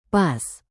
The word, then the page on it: pas: Indonesian